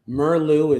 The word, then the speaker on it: Meerloo: Yeah, but there's a psychological angle to that too, because if you go to the book by Dr. Joost Merlu, so yes, an odd name, I'll spell it for you, J-O-O-S-T, Joost. Merlu is M-E-E-R-L-O-O.